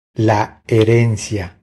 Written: La herencia

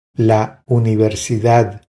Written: La universidad

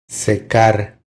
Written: Secar

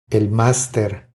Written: El master

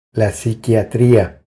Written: La psiquiatria

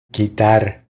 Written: Quitar

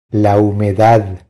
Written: La humedad

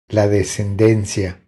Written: La descendencia

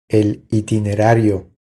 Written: El itinerario

